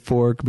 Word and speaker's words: Norfolk: It was very funny because as he's showing up, she's explaining like, oh, it's spelled Norfolk, F-O-L-K, but they pronounce it fork because reason.